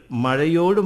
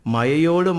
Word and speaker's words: മഴ: പള്ളിക്കൂടത്തിൽ ഭാഷാശുദ്ധി പരിശോധിക്കാൻ വന്ന ഇൻസ്പെക്ടർ കുട്ടികൾ മഴയോട് മഴ എന്ന് പറയുന്നതിന് പകരം മയയോട് മയ എന്ന് പറയുന്നത് കേട്ട് അധ്യാപകനോട് കാരണം ചോദിച്ചപ്പോൾ തയക്കവും പയക്കവും അങ്ങനെ ആയിപ്പോയി എന്ന് മറുപടി പറഞ്ഞു.